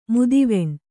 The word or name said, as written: ಮುದಿವೆಣ್